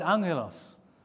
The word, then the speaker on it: angelos: The first thing to say is that our English word, angels, comes from the Greek word angelos. That's a double G, but it's pronounced angelos, which basically means messenger.